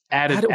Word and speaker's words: Adidon: Because like the story of Adidon, am I saying that right? – Adidon. – Why is that so hard for me? The story of Adidon. – Just think of Adidas.